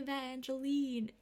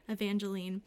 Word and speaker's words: Evangeline: Like, Ray's like, look at my Evangeline. I said that wrong. Evangeline.